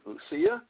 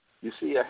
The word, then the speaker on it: Lusia: and I want to take a moment to mention Lucia. I think that's how you pronounce her name, L-U-S-I-A. Lucia Harris, the first woman drafted into the NBA.